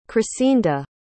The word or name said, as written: chrisinda